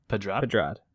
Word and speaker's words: Pedrad: And how do you say Nassim's last name? I don't want to butcher it. – Pedrad.